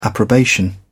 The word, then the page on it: approbation: British English